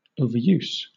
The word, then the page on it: overuse: British English; noun